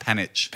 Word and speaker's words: Panic: And well, his name, but he was like, no, it's pronounced Panich.